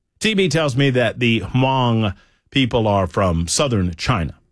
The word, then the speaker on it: Hmong: It's pronounced Hmong.